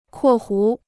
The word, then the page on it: 括弧: kuò hú